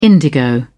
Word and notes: indigo: British English

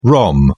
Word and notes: rom: British English

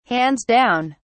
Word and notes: hands down: British English

